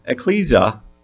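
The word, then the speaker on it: ekklesia: It is Strong's number 1577, and it is pronounced ecclesia in the Greek.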